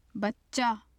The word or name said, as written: bacca